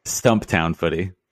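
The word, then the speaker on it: Stumptown Footy: Did I say that right? – Stump Town Footy. – Oh, yeah, Stump Town. Did I say Stumpy Town? – Stumpy.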